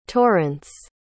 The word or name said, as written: torrance